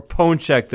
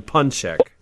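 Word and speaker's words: pączek: So more Poncek than Puncek. – Oh, and there's, it's not really an M. It kind of just goes nasal.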